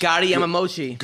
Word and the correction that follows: Goiti Yamauchi: Goiti Yamauchi. – Thank you. – Oh. – Say it right. – Goethe, it's not Gotti? – No.